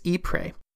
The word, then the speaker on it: Ypres: So I have been pronouncing the name as Ypres, which is the French name for the city. But the city is actually located in the Flemish region of Belgium, and I am told they pronounce it as Ypres, and it is spelled quite differently. Apparently, this is a rather sensitive subject in the region. So from here on out, I'm going to pronounce it Ypres, uh, so don't get confused. Ypres equals Ypres.